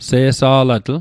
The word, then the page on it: se'sa'latl: Mi'gmaq